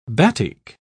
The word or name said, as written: batik